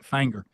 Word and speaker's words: Finger: It's spelled finger, but they call it Fanger.